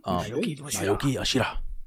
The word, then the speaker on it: Naoki Yoshida: But for now, let's get into news number two with Final Fantasy 16 information from Naoki Yoshida. I hope I said that right. – Mm-hmm. – Naoki Yoshida.